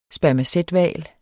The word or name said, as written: spermacethval